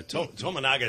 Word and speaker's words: Tominaga: And I know I'm saying it wrong, but I'm Italian. What do I? Tominaga is pretty close, right? – Yes.